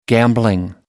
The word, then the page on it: gambling: American English